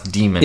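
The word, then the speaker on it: Daemon: We officially said that it's being able to pronounce demon. – It is demon. – It's D-A-E-M-O-N, but it's demon how it's pronounced.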